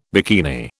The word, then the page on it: bikini: British English